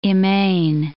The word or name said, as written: immane